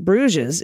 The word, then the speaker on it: Bruges: The S in Bruges is silent, much like the D in the previous episode.